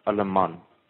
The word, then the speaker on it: Alleman: Is it Alleman or Alleman? – Alleman. – Okay. Spell that for me. Go. And we'll get the upgrade. – A-double-L. – A-double-L. – E-M. – E-N. – A-double-N. – A-double-N. – So Alleman.